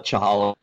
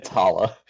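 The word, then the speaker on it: challah: Did you just call it Chalala bread? – I don't know how to pronounce words, so Kahala bread? How okay. do you pronounce it? – It's Hala. – Hala bread? Okay.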